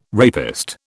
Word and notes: rapist: British English

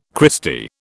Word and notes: Christy: British English

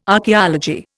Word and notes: archeology: Indian

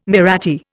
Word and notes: Mahratti: Indian

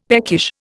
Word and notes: peckish: Indian

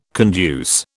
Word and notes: conduce: British English

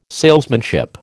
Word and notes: salesmanship: American English

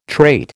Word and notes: trait: American English

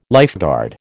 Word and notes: lifeguard: American English

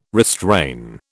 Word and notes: restrain: British English

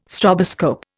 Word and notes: stroboscope: Indian

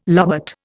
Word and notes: lobate: Indian

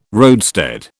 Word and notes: roadstead: British English

